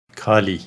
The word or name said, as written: Kali